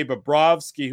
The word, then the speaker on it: Bobrovsky: They could not get anything past Sergei Bobrovsky, who earned his $10 million plus. – Hold on. Say it properly. Say it like Jay Onright says it. – Bobrovsky. – There you go.